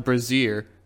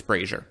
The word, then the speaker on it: brazier: A small shrine with a statuette, a brazier, and an altar dedicated to a god worshipped by many of the dragon's minions. – Fascinating. – I also said it wrong. It's brazier.